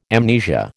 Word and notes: amnesia: American English

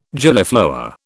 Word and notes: Gillyflower: British English

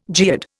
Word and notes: geode: Indian

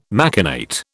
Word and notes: machinate: British English

